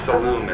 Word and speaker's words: Saloom: My last name is Saloom. It's S-A-L-O-O-M, as in Mary.